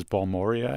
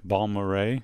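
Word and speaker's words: Balmorhea: The group is Balmoray, who I always pronounce as Balmoria. And just I thought it was Balmoria. – Yeah, see? – It's say it the right way. – Balmoray.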